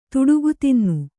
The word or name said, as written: ತುಡುಗುತಿನ್ನು